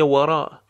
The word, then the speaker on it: وراء: شویه لوراء، شویه لوراء، شویه وراء. کمی عقبتر. شویه وراء، وراء. واف حالت در با تلفظ بر و برخورد دو لب، لبها حالت غنچه ای.